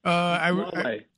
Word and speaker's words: Huawei: And what about the, what is it, Mawa? Am I pronouncing it correct? The phone company? – Huawei.